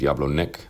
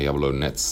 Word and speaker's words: Jablonec: Het is niet Jablonec, maar Jablonec. – Ah. – In Tsjechisch spreek je die C op die manier uit.